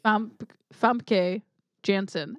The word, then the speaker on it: Famke Janssen: Famke Janssen.